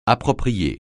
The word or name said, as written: approprier